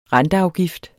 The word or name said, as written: renteafgift